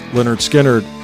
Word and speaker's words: Lynyrd Skynyrd: Freebird, of course, is Ronnie Van Zant and Lynyrd Skynyrd, which came off their debut album, pronounced Lynyrd Skynyrd.